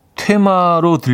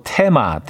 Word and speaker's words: 테마: 자꾸 자전거 퇴마로 들려요. 그래도 잘 알아들었으니 돈 월이 하셨습니다. 아, 자전거 퇴마. 자전거 테마, 테마죠, 테마. 예. 아, 영어로는 띠임이고요.